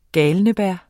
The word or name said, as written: belladonna